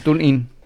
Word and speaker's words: TuneIn: Sí, o sea, comunicó con nosotros, ya nos está escuchando en el ¿qué dijiste? ¿Tunei? – En el TuneIn.